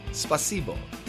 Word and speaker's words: Спасибо: I think the accent goes on the second syllable. Спасибо.